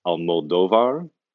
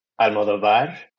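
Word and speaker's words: Almodóvar: As someone who hasn't seen a lot of films by this particular director, Pedro Almodovar, I believe is how it's pronounced. – Not Almodovar? Really sure. we'll run with that. Okay. do you have a third pronunciation for us? – My whole life have said Almodovar. With an emphasis on the final A, but yeah, I'm sure there are many ways.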